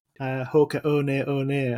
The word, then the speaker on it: Hoka One One: Hoka One One, as I've heard it's now pronounced, and we called it 1-1.